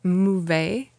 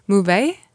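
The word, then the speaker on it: Möwe: It's called, I think it's pronounced Mouve, which means seagull in German.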